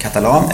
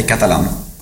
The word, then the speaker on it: Catalan: Alltså man kan ju säga på två olika sätt, Catalan eller Catalan. – Catalan. – Man får bestämma själv. – Vilken är svensk? – Catalan är väl kanske mer svenskt.